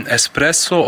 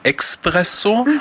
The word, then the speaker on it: Espresso: Enorm falsch ausgesprochen wird Espresso, oftmals wird gesagt Expresso. Wir haben dann noch Prosciutto, bei uns wird oft bestellt Prosciutto.